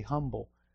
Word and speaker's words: humble: And every time I say humble, I think of Charlotte over here cringing because we are pronouncing the H and she never does. The English teacher over here saying, boy, I wish they'd learned to say it right.